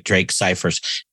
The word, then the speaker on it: Scifers: Drake Scifers, S-C-I-F as in Frank, E-R-S as in Sam.